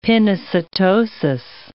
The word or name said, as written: pinocytosis